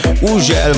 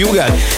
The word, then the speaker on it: Hugel: Így van, az úriember, akinek minden országban másképp ejtik a nevét. Van, ahol Uzsel, van, ahol Hügel, van, ahol Ugel, meg mindenféle kép, de nálunk úgy mondjuk, hogy Hugel, egy Szaltó és egy Jamaican Bam Bam érkezik, a csavarjátok fel a hangerőt.